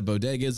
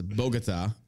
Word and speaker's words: bodegas: The comment, which included Biden mispronouncing bodegas as Bogota.